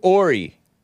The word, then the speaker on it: Horry: Check out Hori. Oh, Ori. I call it Hori. H-O-R-R-Y. Pronounced O-R-E.